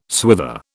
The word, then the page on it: swither: British English